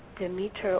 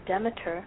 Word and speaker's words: Demeter: And her mother, pronounced Demeter or Demeter, was the goddess of the fields and the harvest and the sun and the seasons.